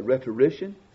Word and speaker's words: rhetorician: As I said before, Ambrose was a rhetorician. How do you say it? Rhetorician? – Yes. – Rhetorician.